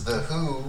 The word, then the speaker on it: The HU: So my album review is The Who, which is... It's spelled H-U, and it's a Mongolian folk metal rock band.